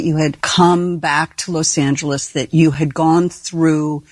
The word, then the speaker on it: Los Angeles: And the other one said, oh, you're saying it wrong. It's Los Angeles, not Los Angeles.